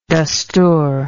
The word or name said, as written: dastur